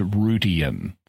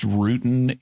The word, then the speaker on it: Rutian: It's Rutian, three syllables. – Yeah. – Because if it was just Rutan, it would be the green glowing squid jellyfish creatures from Doctor Who.